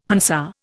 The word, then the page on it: Hansa: Indian